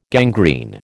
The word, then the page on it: gangrene: American English